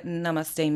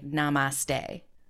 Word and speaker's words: namaste: So in the West, we tend to say hard A, namaste. So I'm just going to honor that. Like, we're Westerners, that tends to be the way that we say it. However, traditionally it is pronounced with like a sound. I'm not going to do this justice, but there's kind of almost like a th sound in some of those languages, Southeast Asian languages.